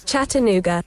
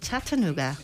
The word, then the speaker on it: Chattanooga: Chattanooga. – A ver, Chattanooga. Uy, qué bien me ha quedado. – Vamos a compararlo en cámara lenta, a ver si lo has dicho igual. – Chattanooga.